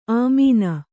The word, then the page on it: ಆಮೀನ: āmīna